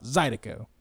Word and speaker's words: zydeco: Yeah, this is just that simply lovely kind of Louisiana music, which I'm going to Zydeco, right? – Yeah, it's the emphasis is on that very first syllable, and the other ones just fall out. Zydeco. – Zydeco.